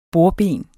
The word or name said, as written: bordben